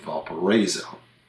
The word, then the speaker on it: Valparaiso: A more obscure example is the city of Valparaiso in Spain. Mm. There was a town in Indiana with the same name, but they pronounce it Valparaiso.